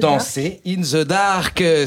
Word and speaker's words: Dancer in the Dark: Danser in the dark. C'est vous qui avez la juste prononciation.